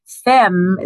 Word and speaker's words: Femme: Soulful, S-O-U-L-F-U-L. Femme is spelled F-E-M-M-E. That's the French word for woman.